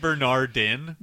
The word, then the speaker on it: Bernardin: Well, then, Mark Bernard is going to get some more Twitter tweets. – You're missing the last syllable of his last name, though. So it's Mark Bernardin.